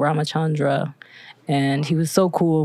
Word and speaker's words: Ramachandra: So Ramakhandra is a Hindu deity. It's pronounced Ramachandra in like the original text, but he has his own sort of, he has like an entire anthology of like stories and like mythical lessons and stuff.